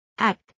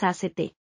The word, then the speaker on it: ACT: Sobre todo, se conoce como adiestramiento en aceptación y compromiso o ACT, que se pronuncia tal como se escribe ACT y no con las iniciales ACT.